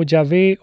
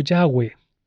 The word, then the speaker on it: Yahvé: y que se pronuncia como Yahvé o Yahweh, en vez de decir Jehová.